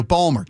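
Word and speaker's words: Baltimore: Well, we just drop syllables. – Yeah, okay. – Syllables is a two-syllable word. You know, Balmer, two-syllable town.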